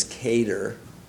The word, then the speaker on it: cadre: In socialism, Terborn argues the kind of leadership you have within the state is what he calls cadre leadership, C-A-D-R-E. England pronunciation is cater, American is cadre.